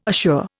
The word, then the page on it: ashore: Indian